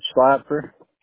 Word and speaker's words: Schlapfer: It's Chad Slapper. Want me to spell that last name? – Yeah, please. – S-C-H-L-A-P-F-E-R.